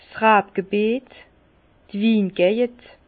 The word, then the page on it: 's Rabgebiet, d' Wingejend: Bas-Rhin; Herrlisheim